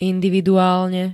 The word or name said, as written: individuálne